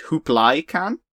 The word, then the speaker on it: Kublai Khan: And then the one after that is about Kublai Khan, you know, of Marco Polo fame. Although, interestingly, that's right. meant to be pronounced Hooplai Khan.